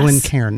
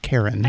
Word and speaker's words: Glencairn: It's a Glencairn, not Karen.